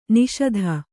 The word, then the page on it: ನಿಷೀಧಿ: Kannada